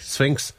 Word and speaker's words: Sphinx: I was going to say, but I'm pronouncing it wrong. I was going to say, before you had that Sphinx cat kind of whole thing going on, he said it's, what you call Sphinx. – It? Sphinx? – Yeah. – Patelno. What is it? How do you spell it? – S-P-H. – Yeah. – Oh, it's S-P-H, is it?